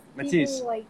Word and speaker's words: Matisse: Is it Mattis or Matisse? How are you saying it? – Matisse. Like in Guatemala, it's very hard for people to pronounce it because my mom is from Quebec. – I see. – So, and it's like a typical name, but they always call me like Matias, which is like the Mattis of Guatemala.